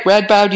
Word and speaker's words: Radboud: It's amazing to me that Radboud, am I saying it right? Radboud University? – That's right.